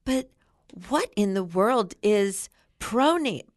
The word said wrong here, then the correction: pronoia: Am I even saying it right? – Pronoia is how I... – Pronoia. – Yeah.